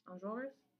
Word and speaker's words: Enjolras: Enjolras? – Yeah.